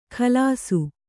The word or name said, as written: ಗಲಾಸು